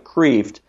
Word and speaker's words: Kreeft: His last name is K-R-E-E-F-T. It looks like Kreeft, but it's pronounced Kreeft, Peter Kreeft.